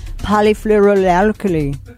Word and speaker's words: Polyfluoroalkyl: Polyfluoroalkyl. That's the correct pronunciation.